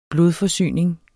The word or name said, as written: blodforsyning